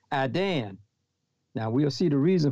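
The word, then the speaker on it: Iddan: Now, this word times in this text, 7:25, comes from the word Adan, Adan, and it is spelled I-D-D-A-N, I-D-D-A-N, Adan, and it carries the meaning of an appointed time or season as it relates to a festival day.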